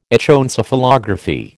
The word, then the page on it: Echoencephalography: American English